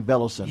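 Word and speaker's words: Velossent: So I've been pronouncing it wrong all this time because I keep saying Velocent.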